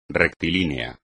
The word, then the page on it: rectilíneo: espagnol